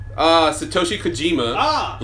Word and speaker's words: Satoshi Kojima: Satoshi Kojima, legend of New Japan Pro Wrestling, versus John Moxley.